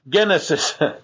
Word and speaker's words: Genesis: In fact, the English title of the book, Genesis, comes from the Greek title of the book, Genesis. The only difference is how we pronounce the G.